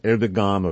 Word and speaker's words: Erdogan: This is worth noting because the same pan-Turkish ideology that Erdogan is, and by the way, E-R-D-O-G-A-N, I mispronounced that in the past, I call him Erdogan or then Erdogan. Apparently, it is pronounced Erdogan.